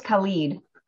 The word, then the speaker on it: Khaled: DJ Khaled. I don't know if I'm saying that right because I'm obviously over 40. – Is it K? – It's K-H-A-L. – I think it's Khalid.